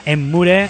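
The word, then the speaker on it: Emmure: And if it makes you feel bad, Emure o Emure o como lo quieras pronunciar, pero escrito así con dos M's y como suena, Emure.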